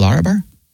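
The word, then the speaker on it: Larabar: Lara? – Isn't it Lara bar? – Yeah. – How do you spell that? – L-A-R-A. – L-A-R-A.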